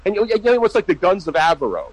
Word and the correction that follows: Navarone: Is that how you pronounce it? – Navarone. – Avarone, that's it. – No, Navarone.